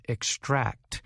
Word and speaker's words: extract: So when using the word to describe taking something out, we emphasize the last part of the word, extract.